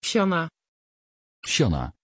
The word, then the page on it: chana: Dutch